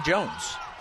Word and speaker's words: Joens: And again, her name is spelled J-O-E-N-S. But said Jones.